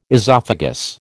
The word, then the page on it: esophagus: American English